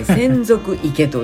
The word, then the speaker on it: 洗足池: 洗う足の池と書いて専属池と読む